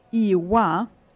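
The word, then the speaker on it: Oíche mhaith: And my favorite expression is Iwa.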